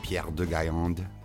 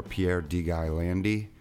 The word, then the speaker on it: Pierre de Gaillande: My real name is Pierre de Gaillande. That's how you say it in French. Or Pierre de Gaillandi. Or Pierre de Gaillande. However you want to say it, this is my podcast.